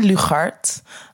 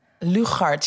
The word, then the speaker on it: Lugard: So my name is Vennie Lugard. Lugard, yeah, it's hard.